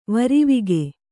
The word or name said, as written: ವರಿವಿಗೆ